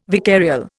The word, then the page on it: vicarial: Indian